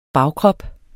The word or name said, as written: bagkrop